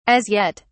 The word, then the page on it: as yet: British English